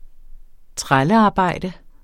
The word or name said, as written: trællearbejde